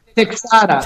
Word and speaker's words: Texada: I don't want to butcher this, because I'm terrible at pronouncing names, but is it Texada? Texada? How do I say that? – Texada. – Texada. All right, cool.